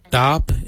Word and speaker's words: Dhaab: Darp is spelled D-H-A-A-B.